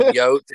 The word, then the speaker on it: Yotes: I said, how do you say it? And they both looked at me and said, Yotes.